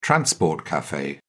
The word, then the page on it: transport cafe: British English